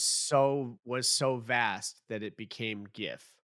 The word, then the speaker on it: GIF: Is GIF-y. – Obama took a side Obama in 2014 announcing his official position is that it is pronounced GIF like a hard G, like a grape.